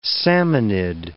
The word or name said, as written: salmonid